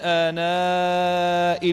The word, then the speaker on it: آناء: كلمة آناء نحن نكتبها آناء بهمزة بعد الألف على السطر، لكنها في هذه الآية في سورة طه زيدت فيها ياء، ولكنها لا تنطق، ما زيدت إلا في الكتابة فقط، ما زيدت إلا في الكتابة فقط.